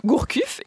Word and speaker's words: Gourcuff: Sinon, on peut noter que Gourcuff est encore blessé.